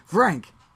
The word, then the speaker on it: Vrank: No, it's not. You just say it. It's like Vrank. – Oh, V F R. – No, V R A N K. Vrank. – Oh, I was Frank. Thinking Vrank.